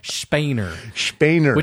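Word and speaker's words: Spener: And we were going to guess on how to say his name, but we listened to some German people speaking English, and they said Spainer.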